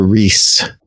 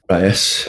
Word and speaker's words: Riess: Is it Rice or Reese? – Rice. – R-I-E-S-S.